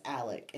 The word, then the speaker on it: Alex: Which is spelled A L E X goes by Alec. Yeah. It's pronounced Alec in the Low Country.